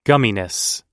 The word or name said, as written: gumminess